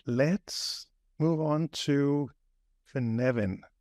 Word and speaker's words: Cynefin: So that's how I heard him pronounce it. You know, watch videos on YouTube, and I'm sure you can see it in the link below. But yeah, that's how I pronounce it as well, Cynefin.